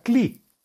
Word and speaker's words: Kli: Ahí están las letras Haf Lamed Yud, que se pronuncia Kli, y es el nombre de Dios número 18, que trae la acción de construir la vasija espiritual que contendrá la bendición.